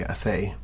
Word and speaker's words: Acei: I think some people say Acei. – Acei? A-C-E-I. Okay. – And some people say Acei. You never know.